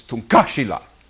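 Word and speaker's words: Tunkašila: you pronounce Lakota terrible. I was saying, Tunkashila. She said, it's Tunkashila. Tunkashila. You have to say it that.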